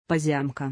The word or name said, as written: Pozyomka